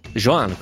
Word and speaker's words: Joan: Ma ancora, la libertà che guida il popolo di Delacroix, Chiara, Giancarlo, Davide con la testa di Golia di Caravaggio, Salvatore, silenzio di Jean Miró. Joan, quindi la pronuncia è Joan.